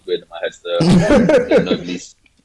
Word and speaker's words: Noblesse: Anyone else watching Noblesse? – Yeah, I'm watching Noblesse as well. – This guy's watching. – It's pronounced Nobles. – It's pronounced Nobles. All right, Nobles.